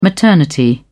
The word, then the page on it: maternity: British English